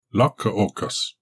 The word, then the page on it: la-ko-kus: Galindri